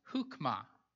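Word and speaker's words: chukmah (חכמה): The wisdom word, or the word for wisdom in Hebrew, is a word that's pronounced chukmah. Chukmah.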